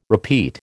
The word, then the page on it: repeat: American English